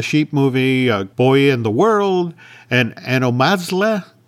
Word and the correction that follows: Anomalisa: Am I saying that name wrong? I'm saying that name wrong. Anomalisa.